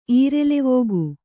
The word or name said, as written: ಈರೆಲೆವೋಗು